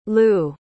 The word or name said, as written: Lou